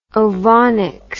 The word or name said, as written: ovonics